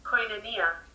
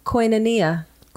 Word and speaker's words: Koinonia: Koinonia. – Koinonia.